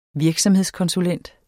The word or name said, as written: virksomhedskonsulent